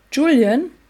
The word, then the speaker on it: Julian: Julian? – Ja, Julian, Julian heißt der. Ja, der spricht sich so ah, englisch ja.